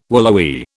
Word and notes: willowy: British English

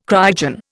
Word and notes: cryogen: Indian

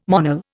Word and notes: Monel: Indian

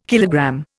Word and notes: kilogram: Indian

